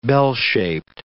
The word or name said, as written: bell-shaped